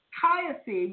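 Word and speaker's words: Kyphi: Kaifi, which is my pronunciation because it's my blend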